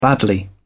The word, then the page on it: badly: British English